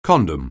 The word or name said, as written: condom